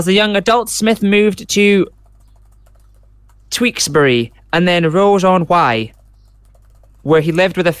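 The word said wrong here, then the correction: Ross-on-Wye: Tewkesbury and Ross. Ross on Wye.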